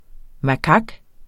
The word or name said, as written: makak